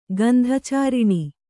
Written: ಗಂಧಚಾರಿಣಿ

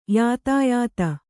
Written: ಯಥಾಯಥ

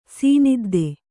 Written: ಸೀನಿದ್ದೆ

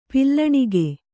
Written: ಪಿಲ್ಲಣಿಗೆ

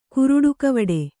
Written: ಕುರುಡುಕವಡೆ

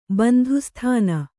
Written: ಬಂಧುಸ್ಥಾನ

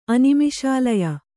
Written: ಅನಿಮಿಷಾಲಯ

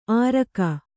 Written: ಆರಕ

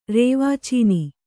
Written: ರೇವಾಚೀನಿ

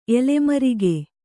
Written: ಎಲೆಮರಿಗೆ

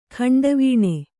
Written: ಖಂಡವೀಣೆ

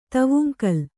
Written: ದುವಂಗುಲ

